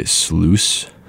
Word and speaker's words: Sluice: The song before that was BD by Sluice, spelled like juice but with an SL in front of it,